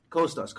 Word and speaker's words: Kostas: Kostas. Kind of like Costa from Tom River Diner.